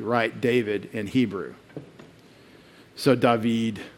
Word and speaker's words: David: You put the accent on the last syllable in Hebrew, generally, not the first. It's not David, it's David. So we say David, but they would say David.